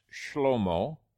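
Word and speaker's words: Shlomo (שלמה): And the name Solomon, which is pronounced Shlomo in the Hebrew, it comes from shalom, shlomo and shalom, very exactly the same root.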